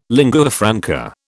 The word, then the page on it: lingua franca: British English